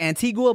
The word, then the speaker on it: Antigua: I think it's spelled like Antigua, but it's Antigua.